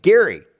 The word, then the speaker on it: Gerry: Well, his name was pronounced with a hard G. Almost everyone these days pronounces it with a soft G, probably because G-E often has a soft G sound, like the word Gentile. But their family still goes by the name Gerry.